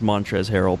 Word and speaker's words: Montrezl Harrell: Montrezl Harrell has a 25.6 PER. I probably butchered his name. Is that why you're laughing? – Yeah, kind of. It's just Montrez Harrell.